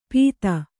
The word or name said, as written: ಪೀತ